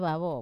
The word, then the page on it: vavo: Wamesa